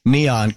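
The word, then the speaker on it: Kneeon: Neon rhymes with neon, like neon lights.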